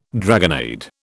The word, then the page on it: dragonnade: British English